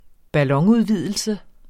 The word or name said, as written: ballonudvidelse